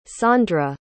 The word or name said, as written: saundra